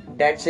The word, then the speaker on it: debt: Okay, then the pronunciation is debt, not be silent in that.